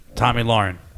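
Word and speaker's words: Tomi Lahren: This chick is completely taking the heat off of Tammy Loren. – Who? – That's not how you say her name. – Tommy Loren? – Tommy Lauren.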